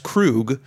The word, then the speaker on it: Kroog: On his own label, pronounced Krug, spelled K R O O G.